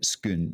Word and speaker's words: Scone: I feel like if you want to learn to speak with a Scottish accent, just saying Scone is a great place to start, isn't it? – I mean, it's pronounced as you know. It's it's spelt scone, but you actually pronounce it skun.